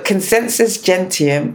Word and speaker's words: Consensus Gentium: So Consensus Gentium is Latin for if everyone believes it, it must be true.